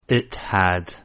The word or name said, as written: it had